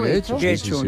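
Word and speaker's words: Getxo: Guecho, yo creo que lo pronuncio bien.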